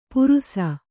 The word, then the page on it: ಪುರುಸ: purusa